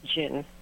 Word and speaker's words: jinn: And we pronounce that word genie, but the singular is really pronounced jinn.